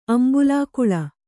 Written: ಅಂಬುಲಾಕುಳ

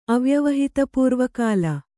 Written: ಅವ್ಯವಹಿತಪೂರ್ವಕಾಲ

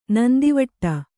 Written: ನಂದವಿಡು

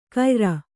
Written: ಕೈಯಾರು

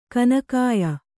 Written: ಗೋನಿಕಾಯ

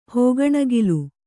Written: ಹೋಗಣಗಿಲು

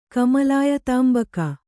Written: ಕಮಲಾಯತಾಂಬಕ

